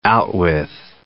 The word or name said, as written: outwith